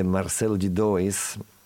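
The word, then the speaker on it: Marcelo D2: Come lui, come Martinho da Vila, anche Marcelo di Dois, si scrive Marcello di Due, come la lettera di Due.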